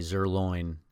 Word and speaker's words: Zuerlein: You want to talk about Seahawks were lucky to beat the Rams because Greg Zuerlein. Why do I always pronounce it that way? Zuerlein, like tenderloin.